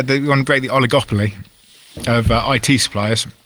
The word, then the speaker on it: oligopoly: Oligopoly.